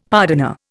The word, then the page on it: pardoner: Indian